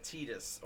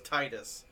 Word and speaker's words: Tidus: No, it's T-I-D-U-S. – Oh, okay. – Yeah, yeah, Tidus. Or I always thought it was Titus. – Titus. – But it's pronounced Tidus, I guess.